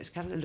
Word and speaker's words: Scarlett: Scarlett, ¿lo he dicho bien? – Sí, bueno, lo hemos entendido.